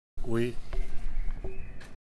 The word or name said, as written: oui